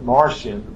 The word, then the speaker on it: Marcion: Not spelled like the little red men from the planet, but Martian, M-A-R-C-I-O-N.